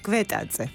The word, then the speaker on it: Gvetadze: Kan je hem zelf nog een keer zeggen? Je achternaam helemaal... – Kwetaatse.